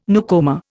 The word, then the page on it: newcomer: Indian